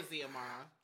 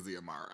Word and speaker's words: Xiomara: Oh, Ziamara? Is that how you would pronounce that? It's spelled... – I say Ziamara. – X-I-O-M-A-R-A. Mm-hmm. And so I'm going to say Ziamara.